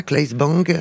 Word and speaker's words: Claes Bang: On prononce comme ça, Claes Bang.